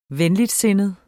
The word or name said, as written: venligsindet